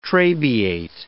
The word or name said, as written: trabeate